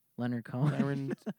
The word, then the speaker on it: Leonard Cohen: Leonard Cohen.